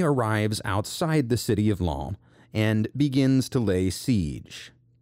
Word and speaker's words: Lens: Lens. That is spelled L-E-N-S, like the English word lens.